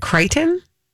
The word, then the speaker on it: Crichton: Is it Crichton? – Yeah.